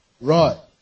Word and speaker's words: Roi: What was the pronunciation? Roi. Very said, we can't say Rohi because there's already Rohi. So it's R-O-I. Ro. What do you call it? Huh? Roy. Roy. Okay. Roy. Roy. E-L-R-O-I.